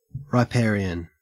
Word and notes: riparian: Australian English

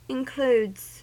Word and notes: includes: American English

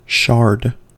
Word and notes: shard: American English

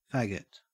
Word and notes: faggot: Australian English

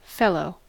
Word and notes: fellow: American English

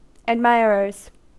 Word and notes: admirers: American English

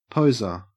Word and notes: poser: Australian English

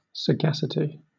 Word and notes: sagacity: British English

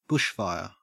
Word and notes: bushfire: Australian English

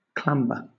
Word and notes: clamber: British English

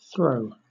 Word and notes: throe: British English

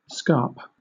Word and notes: scarp: British English

